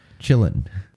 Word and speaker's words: Qilin: It's spelled Q-I-L-I-N. But they pronounce it as... Chillin'. Yeah,